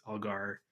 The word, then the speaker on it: Algar: And I believe her last name is just pronounced phonetically, so it's Algar.